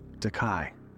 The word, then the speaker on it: Dekye: And took my husband's last name, Dekye. That's spelled D-E-K-Y-E.